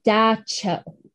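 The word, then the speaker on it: dab tsog: When asked about common nightmares, men and women described a figure called Da Cho. Luckily, they told me how to pronounce that one because it does not look like that. It looks like Dab Tasog.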